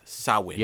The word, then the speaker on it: Samhain: They celebrated Samhain, which is actually spelled Samhain, but it's pronounced Samhain.